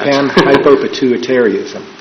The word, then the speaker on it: panhypopituitarism: Panhypopituitarism.